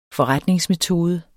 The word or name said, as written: forretningsmetode